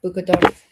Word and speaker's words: Buscadoras: I'm just saying, what in the world could it hurt to have EcuSearch, to have the United Cajun Navy, to have Madres Buscadoras? If I'm saying that right. I think I am. Yes, I am. Buscadoras.